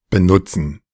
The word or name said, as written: benutzen